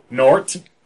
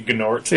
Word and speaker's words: Gnort: Nort. – Gnort. Yeah, you pronounce it Nort? – I pronounce it Nort, but okay. I can see Gnort. – Yeah, I've always pronounced it Gnort.